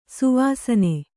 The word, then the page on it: ಶವಾಸನ: śavāsana